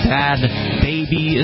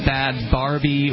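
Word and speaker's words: Bhad Bhabie: And I have been corrected by Bonnie, and the internet has confirmed she is correct that it is Bad Baby is how it is apparently pronounced, not Bad Barbie or Bad Bobby.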